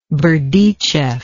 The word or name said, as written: Berdichev